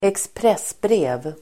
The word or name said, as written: expressbrev